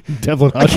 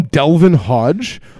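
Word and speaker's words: Devlin Hodges: Duck Dodge. Duck. – Devlin Hodge. – I can't ever say his name. I either call him Delvin Hodge or Duck Dodge.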